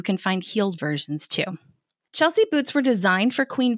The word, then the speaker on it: Chelsea: Chelsea is spelled C-H-E-L-S-E-A and pronounced Chelsea. Now, I figure everyone knows how to pronounce this, but my ninth grade math teacher called a girl in my class Shalessa for an entire year.